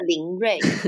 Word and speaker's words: LLLNRY: 那今天呢，有一个这个拼音不太会念的哈，它是什么 L L L N R Y，完全连英文很厉害的老师都，我们两个研究半天都不知道怎么念它。然后呢，我们叫他林瑞。